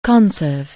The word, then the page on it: conserve: anglais